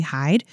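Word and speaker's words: Heide: Hyde, like H-Y-D? – It kind of looks like Heidi, but I looked it up to pronounce it, oh, and okay. It's actually Hyde. It's H-E-I-D-E.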